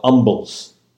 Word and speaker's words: umble: Receiving it, and by the way, these were called umbles. Umbles. So receiving an umble pie, like silent H,